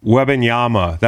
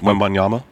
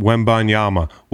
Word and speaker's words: Wembanyama: Webinyama, that's a guy who's not playing. Did I say that correctly? Did I say that? Did I say that correctly? Pretty close. Wembanyama. Webinyama. Wembanyama. Wembanyama.